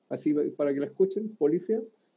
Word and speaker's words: Polyphia: Y en una de esas listas pillé una banda que se llama Polifia, que se escribe con P-O-L-Y-P-H-I-A.